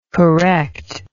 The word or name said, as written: correctingly